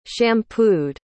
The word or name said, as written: shampooed